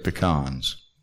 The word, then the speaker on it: pecans: I love pecans, and that is the correct way to say it, pecans.